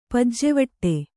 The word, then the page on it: ಪೂಜೆವಡೆ: pūjevaḍe